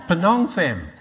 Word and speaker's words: Phnom Penh: She began an orphanage in Cambodia, in Battambang, and on one occasion she was in, do you say it like this, Phnom Penh, is that the capital of Cambodia? Say it again. Phnom Penh? Phnom Penh. Phnom Penh. Thank you.